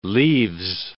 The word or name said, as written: leaves